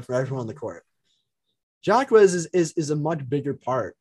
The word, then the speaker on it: Jaquez: It's Jaquez, right? – I think it's Jaime Jaquez. – Jaquez. – Yeah. Jaquez. Silent J always gets me, but yeah.